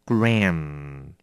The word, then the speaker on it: gram: 这个字也有一点难念，不但要先拉开 a，还要念 M 的，要记得嘴巴请闭好，并且记得鼻子要发出音来哦，所以是 gram gram，有没有听到那个可是不要太多，念成 mu 就 gram，这样就有点难听哦，要念 gram， OK， gram，功课。